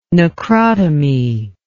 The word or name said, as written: necrotomy